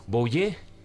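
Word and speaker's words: Vouillé: Bouillé 507, ¿lo he pronunciado bien, caballero? – 507, lo has pronunciado perfecto.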